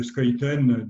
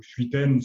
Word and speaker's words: Schuiten: Et puis, il y a aussi un univers qui m'a toujours séduit, c'est l'univers de Skuyten, de Luc ou Schuiten, si vous prononcez la française,